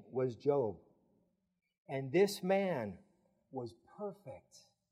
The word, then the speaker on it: Job: His name was Job. Job. J-O-B. How do you pronounce it? Job. I know it sounds like something else.